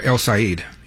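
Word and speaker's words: El-Sayed: Yeah, El Saeed.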